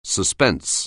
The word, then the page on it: suspense: anglais